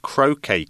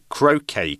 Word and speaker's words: croquet: Wimbledon has been organised by the All England Lawn Tennis and Croquet Club since 1877. So, Croquet Club, not Croquet Club, because it's spelled C R O Q U E T. So, it's a, a tricky word there.